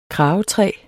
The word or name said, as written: kragetræ